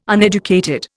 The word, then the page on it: uneducated: Indian